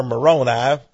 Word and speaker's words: Moroni: Now, very conveniently for Joseph Smith, after he took down all the words on regular paper, the angel Moroni, or Moroni, if you want to pronounce it that way, says, "Thank you very much.